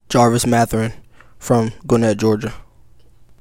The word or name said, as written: Jarvis Mathurin